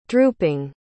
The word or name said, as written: drooping